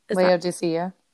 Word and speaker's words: Laodicea: Laodicea. – Laodicea.